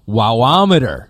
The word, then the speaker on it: WaWa meter: He goes, you're both wrong. It's pronounced Wawometer. – Well, like a thermometer or a speedometer.